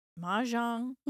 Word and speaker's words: Mahjong: Is it Mahjong? – Mm-hmm. Yeah. – Is that right? – So.